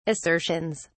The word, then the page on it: assertions: British English